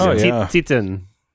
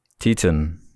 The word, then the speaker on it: Titane: I think it's pronounced Titane. – Titane. – I know, it sucks. It's fucking French.